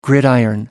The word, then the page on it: gridiron: American English